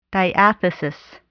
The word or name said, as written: diathesis